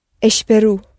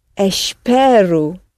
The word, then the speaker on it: espero: Now, with the present tense, I hope or I am waiting, the present tense, we go down on the syllable before last and we surface on the letter O that sounds U. – Espero. – And where's your stress? We want it on the penultimate syllable.